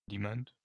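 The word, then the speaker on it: Demant: Ransomware, euh, on a quelques chiffres, euh, concernant, Dement, qui est, je vois comment ça se prononce, Demand,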